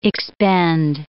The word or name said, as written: expend